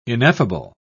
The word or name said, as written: ineffable